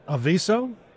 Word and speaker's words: Aviso: He's a CMO of Aviso.